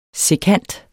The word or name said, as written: sekant